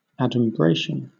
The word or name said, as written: adumbration